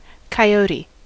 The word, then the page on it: coyote: [kaɪˈoʊ.ti]; American English